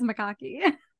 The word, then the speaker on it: macaque: So I guess it is macaque. – Okay, that confirms that we got the pronunciation right. Thank you, at K-A-X-E-M.